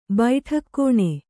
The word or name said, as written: ಬೈಠಕ್‍ಕೋಣೆ